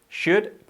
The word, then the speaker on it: should: Primero, tenemos que omitir totalmente la L, tanto en otros verbos similares como es could, podrías, escrito could, o deberías, should, escrito should, la L no se pronuncia. Could, should, por lo tanto está tampoco, would.